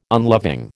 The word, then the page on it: unloving: American English